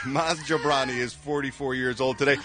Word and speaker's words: Jobrani: Maz Jobrani. – Why are you pausing? – I've been getting some stick from people saying I'm not pronouncing his name correctly. – Well, okay. Don't say Jabroni.